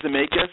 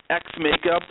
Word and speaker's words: Xamaica: You can pronounce it any way you want. But, you know, I often pronounce it Jamaica. Some kids pronounce it Xmaica. People, I think it's up to the kids to decide how to pronounce it.